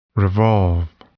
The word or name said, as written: revolve